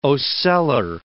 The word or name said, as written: ocellar